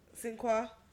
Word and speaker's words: Sinqua: S-I-N-Q-U-A. – Cinqua.